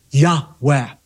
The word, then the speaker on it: Yahweh: which again is pronounced, we believe, a breathy Yahweh.